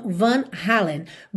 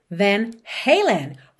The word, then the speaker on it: Van Halen: No Brasil, usamos geralmente uma pronúncia mais abrasileirada, assim como dizemos Facebook e não Facebook. A banda acaba se chamando então Van Halen. But in English, the name of the band is Van Halen.